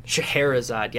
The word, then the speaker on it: Shahrazad: So what other sort of plans do you guys have at Shahrazad? Did I say that right? – Shahrazad, yeah. I mean, there's Shahrazad.